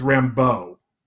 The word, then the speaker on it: Rambeaux: It should be Rambo, not Ram. – Yeah, in my head when I read it in the comics, it was always Rambo. Like the emphasis on the bow, not Rambo, the Force of Freedom.